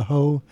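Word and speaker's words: Heaux: That's H-E-A-U-X. Get your mind out of the gutter. The Summoning of the Ho, spelled like go, G-E-A-U-X.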